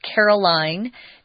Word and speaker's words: Carolyn: We've got two characters this time, Carolyn. This name can also be pronounced Caroline. Depends on the family and what they want. I'm going to say Carolyn.